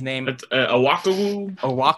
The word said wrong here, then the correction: Okoro: It's Isaac Okoro.